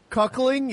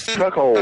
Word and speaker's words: cuckold: Cuckold.